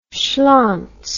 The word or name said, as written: schlontz